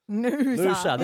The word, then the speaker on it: Noosa: Noosa.